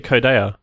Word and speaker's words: Codea: I think he said Codea. – Yeah, I remember that he said it, and I was like, oh, I've been pronouncing it wrong all this time.